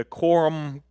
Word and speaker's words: Qumran: These were manuscripts found in one of the caves, the Qumran caves, is how you say it.